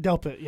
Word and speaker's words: Delpit: but Grant Delpit. – Delpit, yeah.